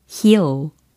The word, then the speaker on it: heel: 굽, 힐이라는 단어는 heel, heel 이렇게 발음이 되는 거죠. H-E-E-L 이라는 철자이고, 그냥 힐 이게 아니고 heal, heal 이렇게 발음이 됩니다. heal, heal, heal 하고 계시죠? 예, here이 아니에요. I'm here 할 때 그 here이 아니고 heal, heal.